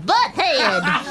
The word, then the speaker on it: Butthead: Ah, uh, excuse me, uh, this is Batheed. – It's pronounced Butthead.